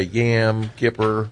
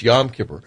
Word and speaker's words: Yom Kippur: Some say Yam Kippur. Yam and Kippers. No, it's Yom Kippur, which is the Day of Atonement, which is a very special day, probably the holiest day there.